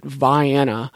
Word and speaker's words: Vienna: It looks like the word Vienna, like Vienna, Austria, but I discovered that people pronounce it Vienna.